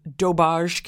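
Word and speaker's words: doberge: And there's another kind of cake that my New Orleans college roommate used to have sent to us called daubage cake. – Daubage? – Or it's spelled like dauberge, but you pronounce it like daubage.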